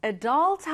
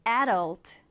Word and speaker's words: adult: 자, 이 단어는 특이하게도 발음하는 방법이 두 가지입니다. 쌤이 방금 발음했던 것처럼 adult 하셔도 되고요. 앞에 강세를 두어서 adult, 이렇게 하셔도 됩니다.